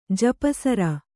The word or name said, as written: ಜಪಸರ